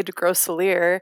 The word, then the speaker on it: Desgroseillier: How do you say it? People always ask. – I know. I say it wrong. I always say Desgroseillier. Chris says Desgroseillier. I don't like how he says it. So I say my own way.